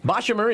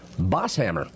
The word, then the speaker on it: Bochimer: Bochimer, although I've been told that he pronounced his name Bosshammer. – Really? – Yep. – Bosshammer, no Bosshammer. – H? – Accent on another syllable.